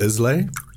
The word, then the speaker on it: Islay: I guess it's just called Isla. It's like the Isle of Skye. Spelled Islay, but it's pronounced Isla.